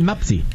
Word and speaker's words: Mpasi: Es más, sí, no va a sonar muy conocido porque la pronunciación es Lionel Mapsi. – Qué capricho. Así parece un capricho. Llama. Lionel Mapsi. – Nazu. – ¿Cómo es el apellido? Mapsi. – Es M-P-A-S-I.